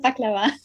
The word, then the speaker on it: Baklava: Die Aussprache wird vielleicht nicht ganz stimmen. Ich spreche das halt ziemlich deutsch aus, aber du wirst mich gleich verbessern. Und zwar lautet das Wortpaar Pide oder Baklava. – Ah, Baklava.